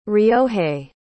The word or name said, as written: ryohei